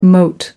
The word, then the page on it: moat: American English